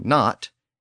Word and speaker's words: know: And English later just dropped that initial K sound altogether, and it became no, K-N-O-W, but pronounced with the N at the beginning. Well, Latin did the same thing with the G-N-O root when it appeared at the beginning of a word.